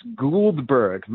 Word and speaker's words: Guldberg: All right, so it's a long U. It's Guldberg, Michael Guldberg, mm.